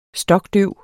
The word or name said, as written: stokdøv